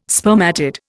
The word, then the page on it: spermatid: Indian